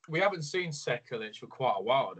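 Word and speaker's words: Sekulić: Dwight Grant versus Stefan Sekukic. I don't think I said that right. – Sekulic.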